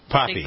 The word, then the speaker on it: Papi: Poppy. – Poppy. – Poppy. – It's spelled P-A-P-I, though. – Yeah, it is, but in Spanish.